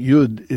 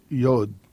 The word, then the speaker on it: Yud: I pronounce it Yud in that Israel, Israelites or Israelis, Israelis pronounce this letter Yud in Israel. But I've heard it also from American Jews on both coasts, from New York and also from Los Angeles, pronounce this Yod.